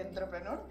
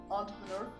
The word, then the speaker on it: Entrepreneur: el honor de ser parte de Entrepreneur. Yo, técnicamente se debería pronunciar entrepreneur, pero entrepreneur también sirve.